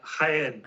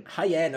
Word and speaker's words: Jaén: How do you say this place? I always get it wrong. Jean. Uh, Jaén. Jaén, of course, it's Jaén.